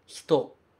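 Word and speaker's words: hito (人): Hito. Hito. A gente escreve hito, H-I-T-O, mas esse I praticamente não aparece. A gente pronuncia hito, hito, hito. Parece só um chiadinho que a gente faz.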